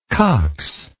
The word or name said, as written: coxless